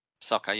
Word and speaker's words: Sokka: Is it the brother's name? Sokka? – Sokka, yeah.